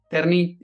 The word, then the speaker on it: Terni: As he was saying, Terni, it's spelled T-E-R-N-I.